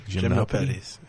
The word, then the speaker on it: Gymnopédies: How do you say that? Gymnopédies? – Gymnopédies. – Yeah, it's French.